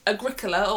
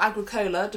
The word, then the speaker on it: Agricola: And that is Agricola, or Agricola, depending on your persuasion of pronunciation.